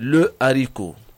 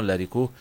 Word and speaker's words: haricot: On dit le haricot. J'ai mangé du haricot, j'ai acheté du haricot. On dit le haricot et non l'haricot.